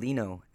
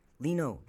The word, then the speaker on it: Lino: You can call me Dr. Lino, Dr. Martinez, or simply by my first name Lino, as in L-I and as in Nancy O. Lino rhymes with vino.